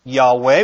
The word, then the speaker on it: Yahweh: The most popular and improper name for God of Israel is Yahweh, pronounced Yahweh, which in Hebrew is written with only four Hebrew letters.